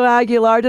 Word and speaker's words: Aguilar: Did I pronounce it right? I don't want to. Mine's Daniela Cenino, and everybody messes that up all the time. Did I say it properly, Cisco? – Absolutely. I mean, obviously you can say Aguilar, but if you want to say it.